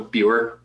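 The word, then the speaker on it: Beur: It's B-E-U-R for spelling, so Bjor, yeah.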